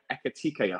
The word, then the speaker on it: Ekitike: Firstly, Fabrizio, can you give us the latest on Newcastle's interest in the Rem striker, Ekitike? I hope I pronounced that right. – Yes, the pronunciation is correct.